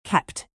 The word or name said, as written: kept